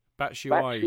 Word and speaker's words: Batshuayi: Is that the official pronunciation? – Yes, Batshuayi? I believe so. Batshuayi.